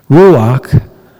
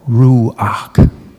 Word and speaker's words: ruach: Ruach, pronounced like that, ruach, is the Hebrew word for spirit, or breath, or wind. And when you say it, you certainly use your breath and your lungs.